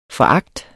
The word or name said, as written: foragt